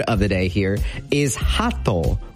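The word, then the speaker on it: hato (鳩): The way to pronounce this is think of like laughing, right? So you go like ha, and then think of like, you know, toe. So like on your foot. So ha. Hato.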